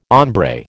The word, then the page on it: hombre: American English